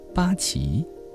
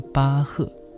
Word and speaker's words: 巴赫: 姑娘瞥了一眼他合起来放在腿上的乐谱，接着问道，巴奇、波奇，这个词怎么念来着？菲利普尽量摆出慈祥长辈的风范，说实际上这个词念巴赫，他是从前一位非常有名的作曲家。巴赫，我听说过这个人。